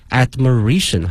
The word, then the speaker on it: admiration: 同时啊，同学们注意一下它的发音，从原来的 admire 变成了 admiration 哈、啊，重音发生了变化。